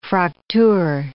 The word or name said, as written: Fractur